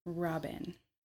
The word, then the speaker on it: Robann: Your name is pronounced Robann. – Robann. There are people who will tell you otherwise.